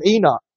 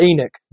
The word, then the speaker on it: Enoch: From Seth, you, from Seth, you have Enoch or Enoch, depending on how you want to pronounce his name.